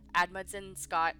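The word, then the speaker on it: Amundsen–Scott: In his work for them, he was assigned to Antarctica Submillimeter Telescope and Remote Observatory, this being run out of the Admundson Scott South Pole Station. I also probably mispronounced that very badly, but... – It sounded right.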